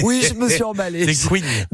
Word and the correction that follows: couignes: Des cougnes, des couignes. oui, pardon, j'ai mal prononcé.